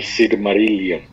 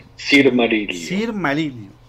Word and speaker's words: Silmarillion: ¿Cómo dices que se pronuncia? – El Silmarillion. – Silmarillion.